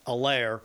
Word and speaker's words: Helaire: And then, of course, Clyde Edwards-Helaire, the H is silent, Helaire, had 138 yards on 25 carries.